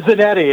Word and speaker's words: Zanetti: It is Zanetti, yes. Okay. Zanetti is correct.